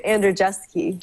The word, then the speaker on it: Andrzejewski: Andrzejewski. – That's probably really close to the Polish pronunciation. – Oh, how do you pronounce it? – We say Andrzejewski.